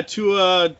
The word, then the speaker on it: Tua: And then on the smaller end of highlights, I don't even know how to say his name. If you want to fucking say it, you can. I don't know. But the Miami Dolphins quarterback. – Tua? – Yeah, Tua. That's how I pronounce it.